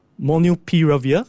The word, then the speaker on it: Molnupiravir: And they are looking to actually import and market its antiviral Molnupiravir, I hope I pronounced it correctly.